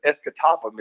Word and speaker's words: Escatawpa: The town I lived in is Escatawpa, Mississippi.